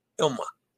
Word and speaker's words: Ilma: oh, it's I-L-M-A, Ilma's float out. Okay, so Ilma. I thought it was L-L-M-A, and I tried looking that up this morning, and there was no L-L-M-A pronunciation anywhere. Okay, so that's I-L-M-A, so that's Ilma's.